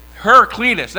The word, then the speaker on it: Heraclitus: Heraclitus? Well, I can't pronounce it. How do you say it? Heraclitus. That's it. I can never get it right.